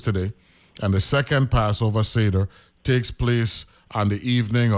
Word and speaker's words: Seder: Even though it's spelled S-E-D-E-R, right? And when I saw it, I said, you know something, let me go look to see how you pronounce this one, because I don't want to make no mistake, right? So I went on Google and it says Seder, right?